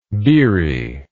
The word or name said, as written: biri